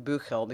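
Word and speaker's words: Büchel: I'm going to pronounce his last name Buchel because it's got an umlaut over the U, so I'm going to go with the German pronunciation.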